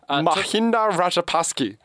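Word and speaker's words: Mahinda Rajapaksa: Mahinda Rajapakse.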